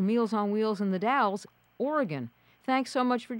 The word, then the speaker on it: The Dalles: Tell us a little bit first where the Dalles, it's spelled differently than it's pronounced, but where is the Dalles?